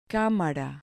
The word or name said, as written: camara